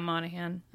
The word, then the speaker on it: Monaghan: Yeah, Monaghan.